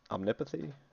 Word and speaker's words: Omnipathy: is it omnipathy? Is that how you say that? Omnipathy? How do you say that? – I think that's correct. Omnipathy.